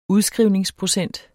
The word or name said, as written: udskrivningsprocent